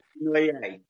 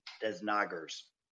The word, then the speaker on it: Desnoyers: Or Desnoyers, correct. And it's okay. That's the French pronunciation. It's been much, much worse. I think the worst I heard was Desnoyers.